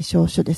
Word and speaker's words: 小暑: そうですね、はい、はい、さて、 1 年を太陽の動きに合わせて 24 の期に分けたおよそ 15 日間にそれぞれ季節の名所を与えたものが二十四節気、七夕の 7 月 7 日からの期間は小暑、小さい暑いと書いて小暑ですね。